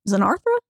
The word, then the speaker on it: Xenarthra: Zen- Xenothara. Is that how you say it? – Xenarthra, I think. Xenarthra. Xenarthra uh, big time simp for his wife Sophia.